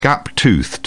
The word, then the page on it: gap toothed: British English